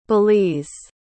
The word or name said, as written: belize